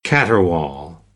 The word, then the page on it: caterwaul: American English; ɑː